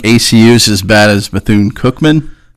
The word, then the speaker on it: Bethune-Cookman: By the way, I pronounce it Bethune-Cookman. – Cooked. – Cookman, yeah, is the way I do it. It's Cookman, but yeah.